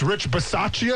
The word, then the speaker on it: Bisaccia: Whether it's Rich Bisaccia, did I say that right, Casey? – Very good.